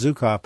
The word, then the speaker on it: Zuckoff: And his last name is spelled, by the way, it's pronounced Zukoff, but it's spelled Z-U-C-K-O-F-F.